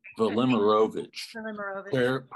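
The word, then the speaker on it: Velimirović: Vellimerovich.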